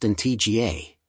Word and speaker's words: Antigua: Antigua, pronounced Antigua, and Barbuda, Barbuda, is located in the heart of the Caribbean Sea.